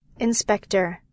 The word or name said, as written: inspector